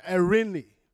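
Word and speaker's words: erini (εἰρήνη): When I did my research, I realized that I was surprised because the word peace in this context in the Greek says erini, the Jewish pronunciation, erini.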